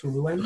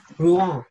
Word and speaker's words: Rouen: I believe it's pronounced Rouen. – Rouen. – There you go.